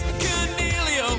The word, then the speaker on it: Coniglio: Stephen Coniglio, or is it Coniglio? There's this name that's been on my mind for some time. It's Coniglio. – Whoa. The commentators watch his game. But some can't even say his name. It's Coniglio.